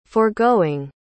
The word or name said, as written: forgoing